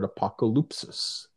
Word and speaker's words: apokalypsis: And it comes to us from the Greek, which is the word apokalypsis.